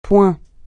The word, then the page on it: point: francais